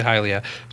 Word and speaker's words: Hylia: You remember running from Lake Hylia, I pronounce it Hylia, some people say Hylia, to Death Mountain.